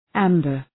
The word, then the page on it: amber: ‘æmbər